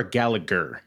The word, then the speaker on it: Gallagher: Is it Gallagher or Gallagher? – Gallagher. – Gallagher, because it was pronounced Gallagher in WWE.